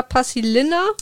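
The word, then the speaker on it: Paasilinna: Das Buch ist von Arto Pasilina oder Pasilina wird dann ausgesprochen.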